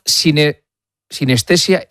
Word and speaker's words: sinestesia: Es lo que los expertos llaman sinestesia impropia. ¿Sí? ¿Lo he dicho bien? – Sí.